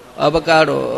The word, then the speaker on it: avocado: It's called avogadro. The first time I am eating that fruit, avogadro. In India, it's very hard to get avogadro. Avocado. Okay. So then, it's pronounced differently.